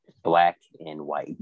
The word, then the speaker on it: BLKNWHTE: It's spelled Blickenwit, but I've been told to pronounce it Black and White.